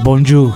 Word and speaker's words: bonjour: O sea, sí es muy parecido el saludo de Italia a Francia, pero se pronuncia de manera diferente. Es bonjour.